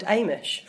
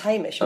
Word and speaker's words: Hamish: I thought that there was a guy called Amish for a long time. Turns out it's Hamish, and I just didn't pronounce the H.